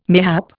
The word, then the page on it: mayhap: Indian